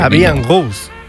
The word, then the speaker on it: La Vie en Rose: La Vie en Rose. No sé si lo he pronunciado bien. La Vie en Rose.